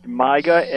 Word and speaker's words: MYGA: I think it's pronounced MYGA, M-Y-G-A, a multi-year guaranteed annuity.